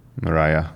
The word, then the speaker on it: Mariah: Mariah Carey, Mariah, Mariah, hogy, hogy ejtik ki jól? Mariah, aha.